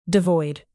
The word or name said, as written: devoid